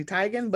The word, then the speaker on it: Teigen: So Miss Chrissy, her pronunciation of her last name is actually Tigan, but she just be answering to Tegan because she ain't got time to be correcting y'all.